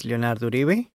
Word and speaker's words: Leonardo Uribe: Yeah, my name is Leonardo Uribe, and everybody call me Leo.